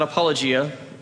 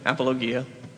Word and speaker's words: apologia: Not apologia, apologia. That's just a pet peeve of mine. There are no hard G or G's in Greek.